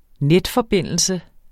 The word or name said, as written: netforbindelse